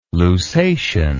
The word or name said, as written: lusatian